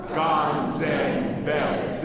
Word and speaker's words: ganzen Welt: Ganzen Welt. – The Z is actually like a TS sound. Ganzen Welt. And the L is almost not, well, it is pronounced, but just the very end.